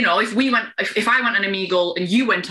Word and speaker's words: Omegle: You know, have you heard of Omegle or Amigo? – No. How do you spell it? – O-M-E-G-L-E. – M-O-G-E-L-E? – O-M-E-G-L-E, Omegle. – O-M-E-G-L-E, Omegle.